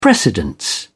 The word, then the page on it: precedence: British English